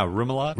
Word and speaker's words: remoulade: It's got a caper remoulade. Is that how you say it? – Yeah, remoulade.